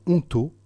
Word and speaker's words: Ontau: Vous êtes chapelain du sanctuaire Notre-Dame de Lourdes et on vous doit cet ouvrage paru aux éditions Onto, qui s'écrit O-N-T-A-U.